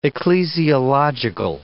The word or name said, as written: ecclesiological